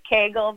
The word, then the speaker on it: Cagle: It's Cagle, but it's fine.